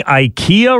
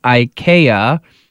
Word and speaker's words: IKEA: The Swedish multinational conglomerate has come out to set the record straight on how to pronounce IKEA, because people weren't only pronouncing IKEA. Some were pronouncing it IKEA or IKEA or IKEA, but it is in fact IKEA. – IKEA. – If you say it in its natural born Swedish language, IKEA is how they pronounce it.